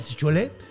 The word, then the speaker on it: Chiloé: Se separa la tierra de, o sea, el archipiélago de, de, Chilo, eh, Chiloé. – Chiloé, perdón. Lo dije mal.